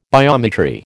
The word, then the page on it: biometry: American English